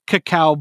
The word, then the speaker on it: cacao: It's pronounced Coco when it's spelled like Coco, but if it's spelled like a dyslexic Coco, it's cacao.